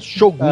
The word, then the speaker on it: Shogun: Não fala Shogun, não existe Shogun. Sílaba tônica não, é Shogun.